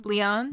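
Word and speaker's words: Lyonne: We have the blind scientist, Summerfield, played by Natasha Loane? – Leon. – Leon, that's the word. That's how you say it.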